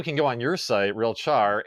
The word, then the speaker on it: RealChar: So are you sort of like insinuating that it gives, like, going through, how do you, is it RealChar or RealCare? How do you say it? – Yeah, it's RealChar. So real time, okay. Yeah, character. Yeah.